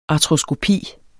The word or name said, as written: ARTROSKOPI